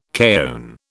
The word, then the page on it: kaon: British English